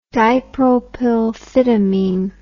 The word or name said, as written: dipropylphyptamine